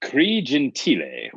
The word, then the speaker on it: Gentile: I have had so many pronunciations, Gentile, Gentile. I say Gentile, so that's fine. The Italian proper way is Gentile, but yes, it's Cree Gentile. – All right, Cree Gentile.